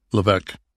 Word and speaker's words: Levesque: Levesque. – You don't pronounce the S, huh? – What do you bottle from him?